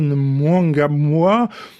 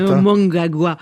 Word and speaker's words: Mnangagwa: Récemment, Robert Mugabe a limogé l'un de ses deux vice-présidents, Emmerson Mnangagwa, réfugié en Afrique du Sud. Je l'ai mal prononcé. – Mnangagwa. – Oui, vous avez raison.